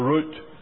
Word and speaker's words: route: Now, for a number of weeks we have looked at Joshua chapter 1, and I have sort of taken the natural route, or route, I think you will pronounce it here in America, but you have to forgive maybe I should forgive your pronunciation.